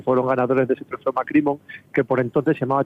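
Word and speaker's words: MacCrimmon: Es que el nombre, no sé si lo digo bien, es Macrimon, creo que es. – Sí, Macrimon.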